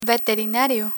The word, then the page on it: veterinario: Spanish